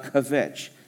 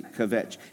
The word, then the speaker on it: kvetch: Say kvetch. It's not two syllables. It's not kvetch, which is a Gentile fallacy. Kvetch. It's kvetch. It's one syllable.